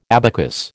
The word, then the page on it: abacus: American English